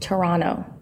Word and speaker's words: Toronto: And fun fact, apparently it's pronounced Toronto. – Like what did I say? – Like that last T is silent. – Oh. – That's how the Canadians say it, apparently.